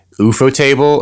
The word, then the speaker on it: Ufotable: Yeah, good on UFO Table I've gone back and forth with how to pronounce the studio's name. Ufotable or UFO table? – I think it's Ufotable.